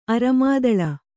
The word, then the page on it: ಅರೆಮಾದಲ: aremādala